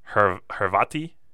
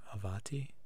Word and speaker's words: Hrvati: And the name came from the difference between the Croatian word for Croats, I'm going to butcher this, but Hrvati. – Hrvati? – H-R-V-A-T-I.